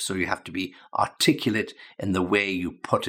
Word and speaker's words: articulate: Now, there is a verb to articulate, and when we're talking about the pronunciation, articulate is the adjective, a different pronunciation, different stress, okay?